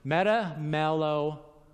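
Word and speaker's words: Metamelomai (μεταμέλομαι): But there's this other word, metamelamoi. I pronounced it. Metamelomai. Metamelomai. Yeah, close enough.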